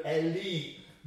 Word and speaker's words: Eli: Rather, we pronounce it Eli, it would be in the Hebrew Eli, my God.